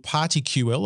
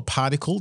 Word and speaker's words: PartiQL: So we are announcing something called PartyQL or Particle, depending on how you want to say it. I haven't been able to nail down what the cool kids are calling it, but it's spelled PartiQL.